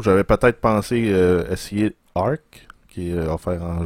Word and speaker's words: Ark: Arc. Arc. Arc. Ça s'écrit A-R-K. donc, comme Arc.